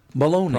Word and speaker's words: Belloni: His name, B-E-L-L-O-N-I. Baloney.